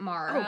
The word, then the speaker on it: Maura: It's because there's a U, so it's M-A-U-R-A. – Yeah. – But I think that U is actually silent.